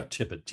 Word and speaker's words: Tippit: Am I saying the name right? – Yeah, Tippett, T-I-P-P-I-T.